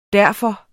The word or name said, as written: derfor